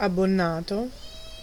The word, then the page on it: abbonato: /ab.bo.ˈna.to/